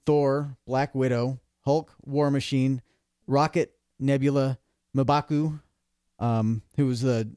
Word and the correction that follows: Mbaku: You need to learn how to pronounce that, but Mbaku, go on. Mbaku. Mbaku.